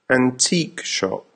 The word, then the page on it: antique shop: American English